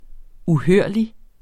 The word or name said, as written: uhørlig